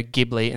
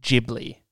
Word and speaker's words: Ghibli: No, you said Studio Ghibli instead of Studio Ghibli because it's pronounced with a J instead of a G.